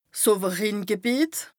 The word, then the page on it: 's Owerrhingebìet: Bas Rhin